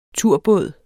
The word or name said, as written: turbåd